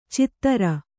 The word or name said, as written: ಛಿದ್ರ